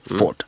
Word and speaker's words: Beaufort: Och det stavas B-A-U-F-O-R-T. Och det är B-O-W uttalas. – Jaja, Beaufort.